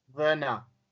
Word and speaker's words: Werner: But Werner. The thing I know about German language is W's are V's and V's are W's.